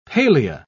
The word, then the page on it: palea: British English